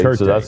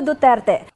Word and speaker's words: Duterte: Okay, Duterte.